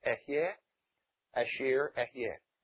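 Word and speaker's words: Ehyeh, Asher, Ehyeh (אֶהְיֶה אֲשֶׁר אֶהְיֶה): Or in Hebrew, that's properly pronounced Ehyeh, Asher, Ehyeh.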